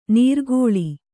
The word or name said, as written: ನೀರ್ಕೋಳಿ